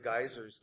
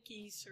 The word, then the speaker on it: Geysir: They say geyser, or so they pronounce it differently, and spelled G-E-Y-S-I-R. That's where we get our word geyser from.